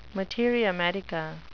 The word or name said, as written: materia medica